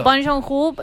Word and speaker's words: Bong Joon-ho: Bong Joon-ho, lo dije bien.